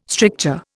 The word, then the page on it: stricture: Indian